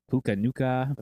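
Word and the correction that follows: Puka Nacua: I'm saying that wrong. Puka Nakua. – Puka Nakua. – There it is.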